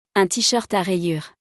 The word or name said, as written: Un t-shirt a rayures